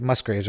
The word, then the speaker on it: Musgraves: So this one comes from the group They, and it features Kacey Musgraves, and the song's called Wait On Me. I heard this song, and I'm just like, wait, They and Kacey Musgraves, I don't know, is it Musgraves, right? – Right.